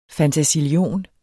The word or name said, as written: fantasillion